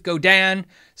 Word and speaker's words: Godin: The only conversation I've ever had, I've had two conversations with Godins, and they were both with Godins, with Godin. Sorry. I've had two conversations with Godin over the years. Both conversations were were when I did the, how do you say their name, which is Godin.